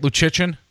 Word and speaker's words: Luszczyszyn: Did I say that right, Luszczyszyn? It, it, it's close enough.